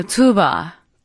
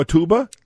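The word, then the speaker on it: Tuğba: T-U-G, the G is silent, B-A. – Oh, T-U-G-B-A. – So the G elongates the U in front of it. – So tuba. – It's tuba.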